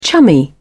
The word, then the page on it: chummy: British English